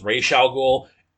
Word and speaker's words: Ra's al Ghul: Now, I really want Matt Reeves to do a movie with Ra's al Ghul so that he can properly pronounce his name as Ra's al Ghul.